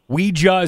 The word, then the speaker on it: Ouija: Ouija is the correct pronunciation? – Yeah, that's the right way to say it. Yeah, it's French and German for yes combined.